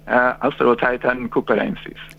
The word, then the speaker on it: Australotitan cooperensis: Senti, il titanosauro è stato trovato ufficialmente nominato Australotitan copernicus. – Ho detto bene? – Australotitan cooperensis. – Eccoci. Poi l'ha detto meglio.